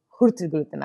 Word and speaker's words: Hurtigruten: Rafat, I read an article about Hurtigruten.